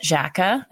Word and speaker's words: Xhaka: I think I read an article a little while ago about Granit Xhaka. That's how you say his name, right? Mm-hmm. Yeah.